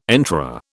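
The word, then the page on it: entrée: British English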